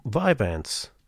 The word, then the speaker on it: Vyvanse: So your ADH medicine, how do you pronounce that? Because you you sent me a text, and to me, just written, it looks unpronounceable. Really? Yeah, it's like a V and a Y and a V together. That's that seems weird. Yeah, Vyvanse. – Okay, Vyvanse. All right, that's that's, that's just what I think.